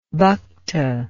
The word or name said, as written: bhakta